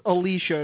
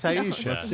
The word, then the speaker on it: Ayesha: No, it's Aisha. – There's no way. – It's Aisha King. Come on. – How do you spell it? – A-Y-E-S-H-A. – That's koala. – What? Is it pronounced Aisha? Are you sure? – Yes, I'm 100% sure.